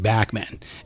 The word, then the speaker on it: Bachman: So I'm talking Randy Bachman, and by the way, that is his preferred pronunciation.